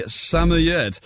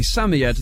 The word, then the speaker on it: Samoyed: a Samoyed. So if you said a Samoyed, then congratulations, you are 100% correct.